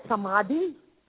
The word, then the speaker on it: Samadhi: What is Samadha? Am I pronouncing it right? S-A-M-A-D-H-I? – Oh, Samadhi? – Samadhi, yes.